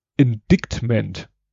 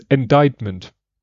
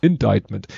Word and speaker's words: indictment: Stellt sich raus, in, äh, das C ist stumm und dadurch spricht man das Indictment. Mhm. Obwohl dann C drin ist. Also C wird komplett so pff, weg ja. damit. Indictment.